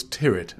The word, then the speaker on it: Tyrwhitt: You've got it spelled right, but you haven't actually pronounced it right. – Gosh, it's Charles Tyrwhitt.